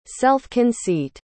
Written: self-conceit

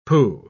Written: pooh

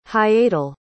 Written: hiatal

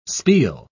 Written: spiel

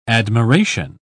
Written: admiration

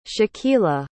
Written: shakeela

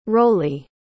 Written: rollie

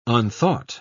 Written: unthought

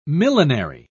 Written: millinery

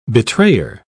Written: betrayer

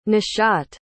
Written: nishat

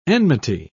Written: enmity

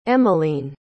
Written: emeline

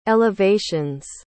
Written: elevations

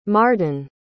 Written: mardon

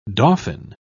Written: dauphin

